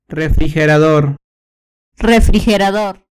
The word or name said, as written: refrigerador